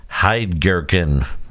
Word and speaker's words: Heidgerken: And I hope that if you've learned nothing else from this podcast, it's how to pronounce Heidgerken. I got it right.